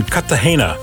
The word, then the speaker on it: Cartagena: Captain Richard Chesbrough is talking to us, and we're talking about you were on the Lady Free, and you're now in Cartagena.